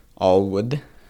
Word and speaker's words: ALWD: The first one is that we have the Sloan book, we have the Tepley book, and then we have ALWD, I think it's Allwood is how they pronounce it, as the acronym.